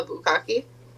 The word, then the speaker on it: bukkake: A bukkake? Am I pronouncing it right? – Yeah.